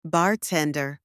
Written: bartender

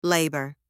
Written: labour

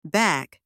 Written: back

